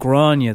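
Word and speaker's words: Gráinne: It happens a lot with Irish people because we've got we've names like Grania that only Irish people really know how to spell. – Yeah. – How do you spell that? – G-R-A-I-N-N-E. – G? – But you'd imagine it's like G-R-A-W...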